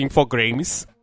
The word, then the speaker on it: Infogrames: E ele foi lançado internacionalmente pela Infogrames. Isso é o nome correto, eu não tô falando errado. Ele é realmente Infogrames.